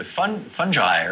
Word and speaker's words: fungi: But going beyond bacteria to fungi, or am I pronouncing that correctly? – There's four correct pronunciations. All of them are correct.